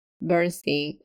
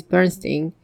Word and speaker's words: Bernstein: Now, somebody wrote, for example, I distinctly remember staring at Bernstein as a kid and wondering why it wasn't pronounced Stain, but Steen.